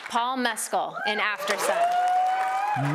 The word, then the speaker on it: Mescal: Well, you have to think about it. It's an Irish. It's not going to be Mescal. Mescal. It's not Irish. You got to say it like if you were saying an Irish accent.